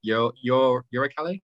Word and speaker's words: Uralkali: I think the thing to consider as well is if the main sponsors, I never know how to pronounce it, Uralkali? Uralkali? – Uralkali. Ural as in the Ural Mountains.